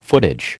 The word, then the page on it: footage: American English